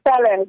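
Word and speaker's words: Kpelle: And you created a label by the name of Moi, which you said means talk in am I pronouncing it right, in Kipele? – It's called Fele. Yeah, Fele.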